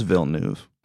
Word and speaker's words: Villeneuve: I think it's just Villeneuve.